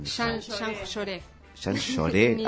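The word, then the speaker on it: Jean Jaurès: ¿Cómo se pronuncia? ¿Jean Jaures o Jean? – Jean Lloré. – Ah, nada que ver. Nada que ver. Jean Lloré, eso es porque no soy de Capital.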